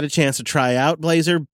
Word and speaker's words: Blazor: And then rather than taking a shot with Blazor, is that how you say it? Probably saying that wrong, because you know me. – Yep, Blazor.